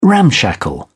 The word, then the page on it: ramshackle: British English